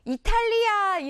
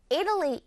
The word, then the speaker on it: Italy: 자, 여러분 이거 이탈리아 이렇게 발음하지 마시고 또는 이탈리 이것도 아닙니다. Italy 이렇게 하는데 요, 딱 보니까 방향이 이쪽인가?